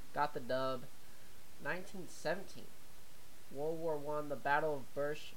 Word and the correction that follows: Beersheba: Beersheba, Beersheba, yeah, I think I said that right, known as the last successful cavalry charge in the history.